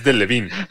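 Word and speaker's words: Delevingne: Cara Deviline no te provoca nada, no tiene nada. – Es muy mala actriz. Por favor, Alex, córtalo ya. Solo porque lo está pronunciando mal, es de Levine.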